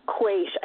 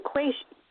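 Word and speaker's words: quaich: I think it's a quaish, which it's a Gaelic word, so it's probably spelled, you know, like S-O-M-E or something. But it's pronounced quaish. I'm pretty sure that's how it's pronounced.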